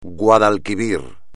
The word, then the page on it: Guadalquivir: espagnol